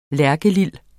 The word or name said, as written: lærkelil